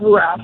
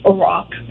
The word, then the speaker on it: Iraq: And it's not Iraq, it's Iraq, that's how they say it.